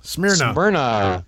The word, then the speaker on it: Smyrna: How do you say that? Smyrna? – Smyrna.